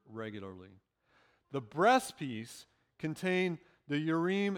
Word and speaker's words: Urim: Now, I know some people say Urim and Thummim. Hebrew, the I is an long E sound, so it's Urim and Thummim.